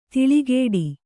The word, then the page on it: ದಳೆಕಟ್ಟು: daḷe kaṭṭu